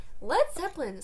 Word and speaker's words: Led Zeppelin: Led Zeppelin spells their name L-E-D instead of L-E-A-D because they were afraid that Americans would say it wrong.